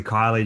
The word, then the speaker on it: Kylie: It sounds like Keely, but it's actually Kylie Justin. But either one's fine at this point.